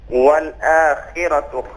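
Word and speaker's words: والآخرة: اس کلمے کی تکرار کیجیے والآخرت. – والآخرت خیر وابقا. – احسن, اس بار آپ کا تلفظ بالکل صحیح تھا.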